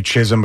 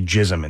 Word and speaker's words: Chisholm: Wanting to say Chisholm, but they say Jism instead.